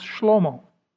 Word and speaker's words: Shlomo: In Hebrew, it's pronounced Shlomo.